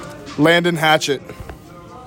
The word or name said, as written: LANDEN HATCHETT